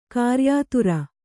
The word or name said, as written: ಕ್ರಯದಾರ